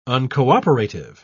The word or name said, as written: uncooperative